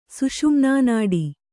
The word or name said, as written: ಸುಷುಮ್ನಾನಾಡಿ